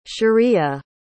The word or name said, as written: shariah